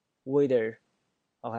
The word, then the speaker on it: waiter: 当夹在这两个元音中间的时候，我要把 T 变成 D 的发音，所以这个 waiter 我就变成了 waiter，OK 啊，clear 意思吗？